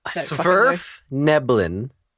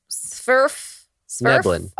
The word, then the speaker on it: Svirfneblin: Every time you say Svirfneblin, you butcher it slightly more every time. – Wait, what is it actually? – Svirfneblin. – Svirfneblin. – All right, yes.